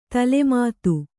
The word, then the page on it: ತಲೆಮಾತು: tale mātu